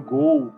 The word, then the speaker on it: Ghoul: O nome é esse mesmo, Ghoul, que fala, e aí foi traduzido virou é, G-H-U-L, sacou? E aí pro inglês virou Gol com O-U por causa da pronúncia